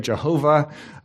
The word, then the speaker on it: Jehovah: It's pronounced often Yahweh or Jehovah.